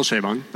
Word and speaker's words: Chabon: It's Michael Chabon.